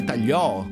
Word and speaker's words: tagliò: Ricordatevi sempre, la parola non si può dividere, ma si può cambiare l'accento. – Ok. – Quindi vedete voi, tagliò e basta.